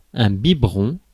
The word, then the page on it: biberon: [œ̃ bi.bʁɔ̃]; [bi.bʁɔ̃]; /bi.bʁɔ̃/